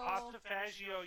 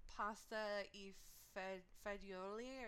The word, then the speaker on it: pasta e fagioli: So, yeah, like if you were saying it in Italian, it would probably be like pasta e fagioli or something like that.